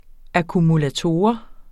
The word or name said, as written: akkumulator